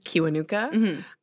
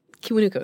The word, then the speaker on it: Kiwanuka: They're like, "You're saying your name wrong. It's Kiwanuka." And it's not Namugenyi. It's like, "Namugenyi Kiwanuka." And I'm like, I'm not going to ask people to do that because it's like, I've always pronounced it Kiwanuka,